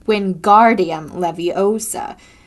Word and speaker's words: Wingardium Leviosa: It's Wingardium Leviosa. You say the gar nice and long.